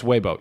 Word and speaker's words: Weibo: Another one though that's super popular is called Weibo, which I think is kind of the equivalent of, pronounced, I believe, more like Weibo. – Is it Weibo? Yeah, it's Weibo. You're right.